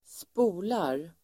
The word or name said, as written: spolar